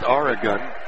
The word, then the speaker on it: Arogun: Last name spelled A-R-O-G-U-N, pronounced Aragon.